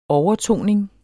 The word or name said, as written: overtoning